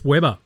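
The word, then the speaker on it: Webber: Is it pronounced Weber? Yeah, Chris Weber.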